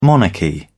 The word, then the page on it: monarchy: British English